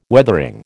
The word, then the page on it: Weathering: American English